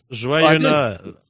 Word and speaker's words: Joyeux Noël: Joyeux Noël. Did I pronounce that correctly? – Yes. Joyeux Noël.